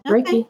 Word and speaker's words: Reiki: It's not how it's spelled, but just to be able to pronounce it, it's definitely a rake with the, you know, like a rake and then E. Reiki.